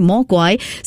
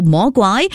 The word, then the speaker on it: Mogwai: But in the show, they actually made use of the Cantonese word of, I think it's Mogwai, right? Mogwai. So it's very cute. They made it a little bit more English and it's spelled Mogwai, which you can pronounce as well, Mogwai.